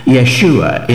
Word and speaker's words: Yeshua: Jehoshua, sometimes pronounced Yeshua in the Hebrew tongue, and discover just why it holds such significance both for time and eternity.